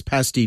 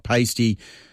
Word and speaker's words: pasty: But please pronounce pasty correctly. It's pasty, not pasty.